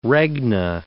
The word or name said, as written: regna